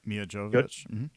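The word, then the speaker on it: Milla Jovovich: You know, I'm not really sure how everybody feels about the Mia Jovovich. Is I say that right? – Yep, Mia Jovovich.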